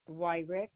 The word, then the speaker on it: Wyrick: Suzanne Wyrick.